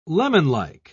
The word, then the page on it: lemonlike: British English